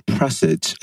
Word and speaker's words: Presage: Episode 0, Presage. Am I saying that right, Lindsay? – Yes, you are.